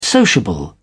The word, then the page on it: sociable: British English